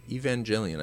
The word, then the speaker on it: Evangelion: Because, like, Evangelion, that's how you say it? – Yeah. – Evangelion.